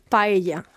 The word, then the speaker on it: paella: Paella. – Okay, I'm never going to be able to. – Paella, yes. So Irish people tend to put two double L's, and it's one. It's paella.